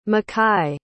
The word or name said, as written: makhi